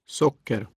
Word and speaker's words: socker: For now, learn to pronounce the words for sugar, water, and bicycle with accent one, a single rise. – Socker.